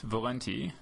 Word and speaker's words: Valente: It's spelled V-A-L-E-N-T-E, but it's pronounced Valenti, not Valente.